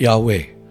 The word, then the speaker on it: Yahweh: The first Lord is translated from the Hebrew name for God, spelt YHWH, often pronounced Yahweh.